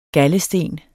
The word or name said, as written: GALDESTEN